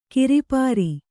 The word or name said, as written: ಕರಪುರಿ